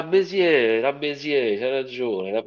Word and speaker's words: Bézier: Credo sia francese, quindi è Bézier.